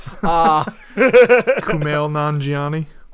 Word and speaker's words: Kumail Nanjiani: It's, do you see, what, Camille? – Camille? – I can never pronounce it. Yeah, I suck. – Kumail Nanjiani. – Thank you. You know, I'm just going to let you say all the names I want to say from now on forever.